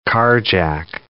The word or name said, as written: carjack